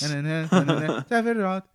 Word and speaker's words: Sephiroth: The original game itself pronounces Sephiroth in One Winged Angel. The yes, it does. – Eswanzi Teresi. – Several times. – Sephiroth. Yeah. So it's in there already. It's the one name that the game pronounces for you.